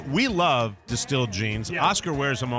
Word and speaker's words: DSTLD: Distilled is spelled D-S-T-L-D. It's Distilled.